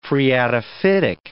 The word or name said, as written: phreatophytic